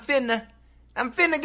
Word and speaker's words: finna: And it sounds like, I'm finna go to sleep. I'm finna go to sleep. And that's what I wrote. I'm finna. I am finna. F-I-N-N-A. That pronunciation is the super fast, perhaps we could say the urban pronunciation in many cases, of I'm fixing to.